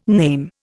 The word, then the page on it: name: Indian